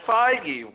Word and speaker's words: Feige: Feige, Kevin Feige. Am I am I right here? – Kevin Feige.